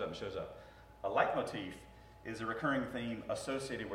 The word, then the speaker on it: leitmotif: It's a leitmotif.